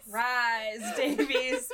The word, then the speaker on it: Rhys-Davies: John Rhys-Davies? – Yeah. – How are you going to say it? – Rhys-Davies.